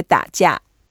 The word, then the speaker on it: 打架: The way to say that in Chinese, we say 打架.